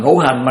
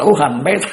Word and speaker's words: ngũ hành: Bà chú sư bà ú hành á, ngũ hành mà nó đọc là ú hành, mấy thằng mà nó học chữ đọc là cò mà nó không học chữ nho đó, chứ ngũ năm của người ta mà ngũ hành ta mà nó đọc là ú hành đó ha.